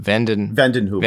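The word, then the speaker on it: vanden Heuvel: Vandenhoevel.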